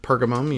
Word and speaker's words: Pergamum: And then, I don't know how to say, Pergamum. – Pergamum, you nailed it. That's how it is.